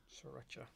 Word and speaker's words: Sriracha: Did I say that right? Sriracha? – Sriracha.